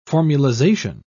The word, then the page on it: formulization: British English